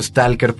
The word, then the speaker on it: stalker: Los personajes que van son un escritor y un científico, y van guiados por el stalker, es decir, el explorador, que yo lo he dicho stalker porque así se dice en ruso, es como la mala pronunciación de la palabra inglesa en ruso.